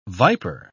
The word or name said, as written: viper